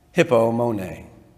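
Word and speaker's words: hippomone (ὑπομονή): hippomone. I know it sounds like currency for hippopotamuses. That is how you pronounce it, I promise you.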